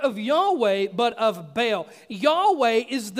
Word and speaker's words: Baal: The right way to pronounce it, we think, is Baal, like with a long A sound. But I'm just going to say Baal because that's the way I learned it for so long, and it's hard to break old habits.